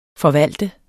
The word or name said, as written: forvalter